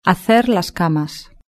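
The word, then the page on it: hacer las camas: Spain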